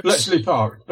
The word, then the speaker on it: Bletchley Park: Bletchley Park Decrypts? – Bletchley Park.